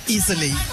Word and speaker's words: Easel E: Easel E. – Pronounced like easily.